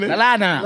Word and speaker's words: Lallana: Lalana, Lalana, Lalana, Lalana.